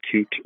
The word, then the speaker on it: QT: There's an update on QT, which I then had to then Googled to figure out whether people pronounce it cute or not, and they do call it cute.